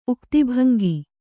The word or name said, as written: ಉಕ್ತಿಭಂಗಿ